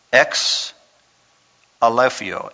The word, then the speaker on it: exaleipho: Ex Aleffio. Ex Aleffio. And I'll spell it for you. E-X-A-L-E-I-P-H-O.